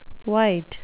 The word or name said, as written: wight